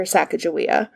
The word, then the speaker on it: Sacagawea: Versus if she was strictly Shoshone, then it would be more with the hard G, and it would mean more like boat launcher, versus in Hidatsa, it would be bird woman. – Huh. – And we don't know. So the Lemmy Shoshone call her Sacagawea. – Okay. – And most people spell it Sacagawea, but pronounce it with a J.